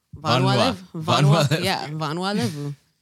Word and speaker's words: Vanua Levu: part, you gotta well, roll like, your tongue kind of, real yeah, quick like, in a certain way to say it right. Vanua.